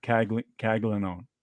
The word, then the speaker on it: Caglione: It was, if you guys don't know this name, and I might have pronounced it wrong, I didn't look up Caglione, it's spelled Caglione, but yeah. I think it's Jack Caglione.